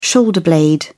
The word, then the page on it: shoulder blade: British English